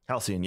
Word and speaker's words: Halcyon: Halcyon, yeah.